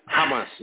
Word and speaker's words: Hamas: In Gaza, Hamas, I guess that's how you pronounce his name, Hamas? – Hamas, yes.